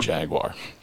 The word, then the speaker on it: Jaguar: We've been saying it right the whole time, and there's a big fat W in there. I encourage those who are doubters, do your own little etymology search, and you will find that indeed pronounced as the word was originally formed. There is indeed a W in Jaguar.